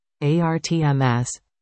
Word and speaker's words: ARTMS: ARTMS, pronounced like Artemis, the Greek goddess of the hunt, specializes in technology that produces the technetium-99m medical isotope.